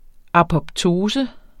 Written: apoptose

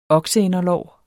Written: okseinderlår

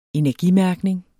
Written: energimærkning

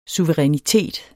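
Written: suverænitet

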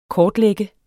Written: kortlægge

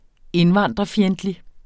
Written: indvandrerfjendtlig

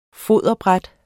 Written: foderbræt

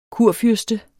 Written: kurfyrste

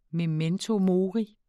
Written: memento

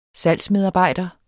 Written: salgsmedarbejder

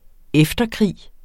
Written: efterkrig

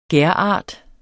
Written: gærart

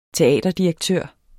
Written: teaterdirektør

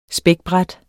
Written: spækbræt

